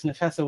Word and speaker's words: Nafessa: It also stars Miss, is it Nafessa? She's from Black Lightning, right? – Yes, Nafessa Williams.